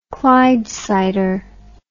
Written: clydesider

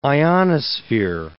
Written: ionosphere

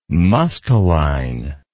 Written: musteline

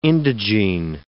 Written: indigene

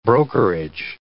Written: brokerage

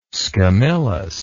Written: scamillus

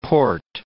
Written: Porte

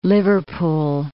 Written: Liverpool

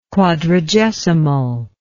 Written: quadragesimal

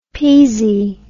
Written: peezy

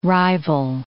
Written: rival

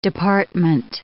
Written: department

